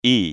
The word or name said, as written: i